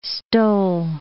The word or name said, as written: stole